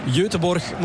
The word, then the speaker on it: Göteborg: En de normale uitspraak zou Göteborg moeten zijn. Maar we gaan het maar bij onze Vlaamse uitspraak houden.